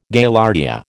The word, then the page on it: Gaillardia: American English